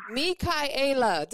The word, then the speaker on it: Mikaela: So, you know, Mikaela.